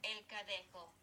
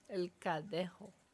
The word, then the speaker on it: El Cadejo: C-A-D-E-J-O. – Thought it was Gaelic. Spanish. Give me Spanish. – Espanol. – El Cadejo. – El Cadejo.